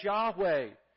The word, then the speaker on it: Yahweh: We would pronounce those Hebrew letters Yahweh.